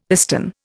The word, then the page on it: piston: Indian